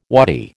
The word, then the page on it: wadi: American English